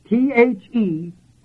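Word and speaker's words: Theoreo (θεωρέω): The third usage of this word beholding in the Bible is this word, theoneo. And that word is spelled theoreo. This is an R. Theoreo.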